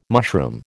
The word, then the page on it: mushroom: American English